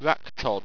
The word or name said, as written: wractod